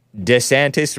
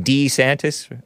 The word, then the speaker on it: DeSantis: Ron DeSantis, who reportedly changed the way he pronounced his name, the way his wife Casey preferred saying it. DeSantis rather than DeSantis. Ron DeSantis, he used to call himself. But she prefers DeSantis.